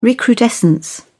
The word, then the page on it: recrudescence: British English